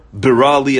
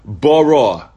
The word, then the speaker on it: bira: So sometimes, I heard recently when Moshe Weinberger said, people mispronounce the word. They say, lev tahor, a heart, bara, you did create in me. No, it's bira, it's a request.